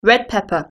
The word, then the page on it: red pepper: British English